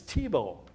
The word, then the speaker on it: Tebow: But if you got to think of Faribault, Minnesota. And if you look at that name, that will help you a little bit, but it's Tebow. That's how you pronounce the last name, Tebow.